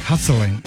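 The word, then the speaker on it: Hessdalen: Se llama el Valle de Hadsland. Hadsland, sí. Así se llama. El Valle de Hadsland. Se escribe Hesseldalen.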